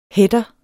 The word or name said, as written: header